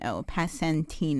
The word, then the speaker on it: Passantino: How's it spelled? – P-A-S-S-A-N-T-I-N-O. Passantino. – Passantino. – Okay, great. – You got it.